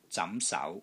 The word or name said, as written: ZAM2 SAU2